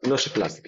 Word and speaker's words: nociplastic: Did I say it right? Nocioplastic pain? – Nocioplastic, yes.